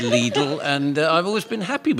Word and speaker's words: Lidl: Lidl, like little. – But don't they say Lidl, the Germans? – They say Lidl because it's spelt little, which is L-I-D-L. Whereas Lidl would be L-I-E-D-L.